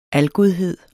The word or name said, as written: algodhed